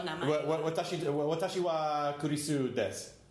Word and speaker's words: desu: Right, des, and it's D-E-S-U, but you don't pronounce the U.